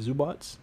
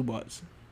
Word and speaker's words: Zubac: Yeah, you got my boy Zubac. – You mean Zubats? – Zubac. How you pronounce it? – Zubac? It's definitely Zubats.